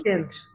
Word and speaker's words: prescient: Is that how you pronounce that word? – Prescient. – What? – Prescient. What you said? – Prescient. – Shockingly correct. Huh.